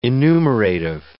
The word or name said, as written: enumerative